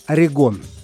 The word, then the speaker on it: Oregon: Это американская группа Oregon – или в соответствии с русским произношением названия одного из штатов на северо-западе США – Орегон.